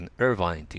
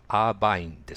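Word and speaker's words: Irvine: で、私がカリフォルニアで住んでた町は、イルヴァインって言うんですけども、まあ、カタカナ発音はアーバインですね。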